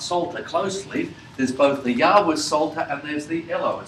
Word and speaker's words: Yahweh: We assume that the right pronunciation is Yahweh.